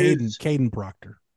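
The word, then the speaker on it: Kadyn Proctor: It's Jaden Proctor. He, if you look at allowed pressure, Caden Proctor. – Caden Proctor. Sorry, I mispronounced his name, but he's ended up allowing 33% of the allowed pressures last week against Middle Tennessee State.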